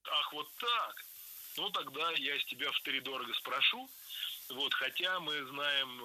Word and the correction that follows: втридорого: Втридорого – это неверно. Правильный вариант – с ударением на первом слоге. Втридорого.